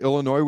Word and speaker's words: Illinois: And I think they're going to beat Illinois with the S is silent.